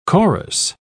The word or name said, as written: chorus